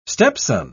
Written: stepson

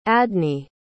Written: adne

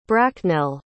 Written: bracknell

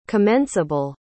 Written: commenceable

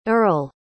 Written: earle

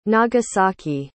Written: nagasaki

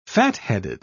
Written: fatheaded

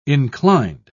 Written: inclined

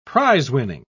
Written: prizewinning

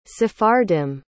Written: Sephardim

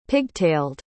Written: pigtailed

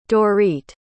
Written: Dorit

